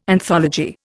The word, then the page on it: anthology: Indian